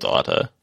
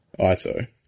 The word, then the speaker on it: Ito: So, on the bench, they obviously had Minamino, Junya Ito. Again, apologies for mispronunciation. – I believe it's Ito. – Ito.